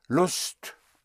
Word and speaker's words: Lust: Lust is spelled L-U-S-T. It sounds like lust. So I have no lust, but it does not have the implication of lust.